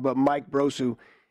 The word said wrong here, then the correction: Brosseau: he's playing second base. – I think it's Mike Brosseau.